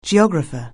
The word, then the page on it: geographer: anglais